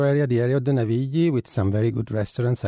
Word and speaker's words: Navigli: Navigli.